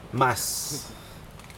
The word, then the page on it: mas: Aneityum